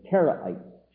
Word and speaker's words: Karaite: Q-A-R-A-I-T-E, the Karaite Jews. Now this word you'll sometimes see it spelled with a K, sometimes with a K with a dot under, sometimes with a Q. Q-A-R, or K-A-R if you prefer. Q-A-R-A-I-T-E. Karaite.